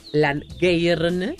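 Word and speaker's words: Llangernyw: Luego tenemos un árbol que se llama Tejo Langeirne que está en Gales y no sé pronunciarlo como se puede pronunciar en galés. – Lo dijiste muy bien.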